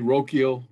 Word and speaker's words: Rocchio: I would go Rocchio. Did I say that right? – Rocchio. – Yeah, Rocchio Valera.